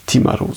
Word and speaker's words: Timaru: Und da habe ich nochmal gelernt, aber das ist für mich auch neu, dass man die meisten Maori-Wörter auf der ersten Silbe betont. Dann müsste es eigentlich Timaru sein.